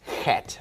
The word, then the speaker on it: chet: And it's like the Greek he, and it is chet. Eighth letter is chet. All right, the ninth letter rhymes with chet, and it looks like that, and it is tet, tet.